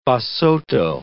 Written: Basotho

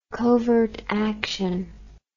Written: covert action

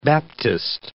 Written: Baptist

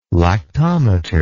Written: lactometer

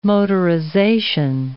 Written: motorization

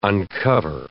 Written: uncover

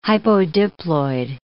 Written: hypodiploid

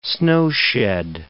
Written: snowshed

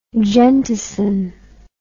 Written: gentisin

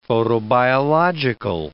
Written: photobiological

